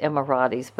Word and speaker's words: Emirates: Some people pronounce it Emiratis, but I say Emirates. I hope that's right.